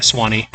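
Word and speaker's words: Sewanee: Yeah, Swanee.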